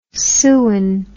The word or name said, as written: sewen